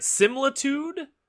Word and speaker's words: Similitude: And we're talking about how do you say it? Similitude? – That's how I would say it.